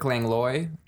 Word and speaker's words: Langlois: His name is Eric Langlois. I'm sorry if I'm butchering his last name. It's spelled L-A-N-G-O-I-S. Is that right? – L-A-N-G-L-O-I-S.